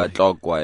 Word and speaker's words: Batlokwa: The Batlokwa, I hope I'm pronouncing that correctly, people. – Batlokwa, yeah.